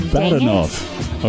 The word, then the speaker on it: Badenov: Badenov.